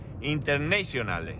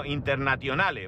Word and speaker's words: Internationale: Internationale, no, Internationale, ¿vale? No es en inglés.